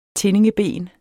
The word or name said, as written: tindingeben